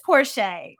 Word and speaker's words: Porcher: It's Porcher, Nikki Porcher.